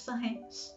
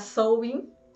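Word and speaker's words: Samhain: No Halloween, por exemplo, durante Sanren. E, gente, lá vamos nós de novo, não conseguindo pronunciar Sowin de maneira correta. Espero que dessa vez eu tenha conseguido.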